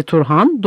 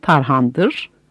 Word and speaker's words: Turhan: Annesi Turhan, doğru okunuşu Tarhan'dır.